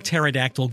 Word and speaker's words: pterodactyl: Why can't you hear a pterodactyl go to the bathroom? Okay, again, why can't you hear a pterodactyl go to the bathroom? Silly, the P is silent.